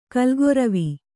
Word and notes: ಕಾಲ್ಗೊರವಿ: kannada; kālgoravi

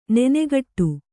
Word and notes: ನೆನೆಗಟ್ಟು: nenegaṭṭu

